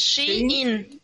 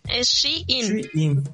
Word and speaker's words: Shein: ¿Cómo lo pronuncian ustedes, amigos? ¿Shin? – Es Shein. – Shein, sí, ¿verdad? – Pero le dice la gente Shein.